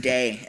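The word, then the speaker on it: day (δεῖ): The word must there, and we'll touch on this several times throughout this series, but it's pronounced day in the Greek.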